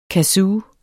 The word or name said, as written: kazoo